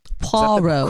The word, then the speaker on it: Poirot: Is that how you say it? – Poirot. – Correct.